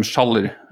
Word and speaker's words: Schaller: I pronounce my surname Schaller. technically speaking, I'm not sure if it's correct in the sense that it's a German surname, and I probably pronounce it in a very Norwegian way, but that's how I pronounce it.